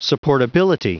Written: supportability

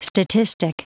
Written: statistic

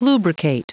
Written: lubricate

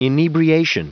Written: inebriation

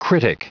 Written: critic

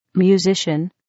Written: musician

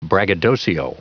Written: braggadocio

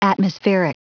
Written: atmospheric

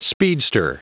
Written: speedster

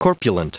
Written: corpulent

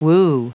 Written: woo

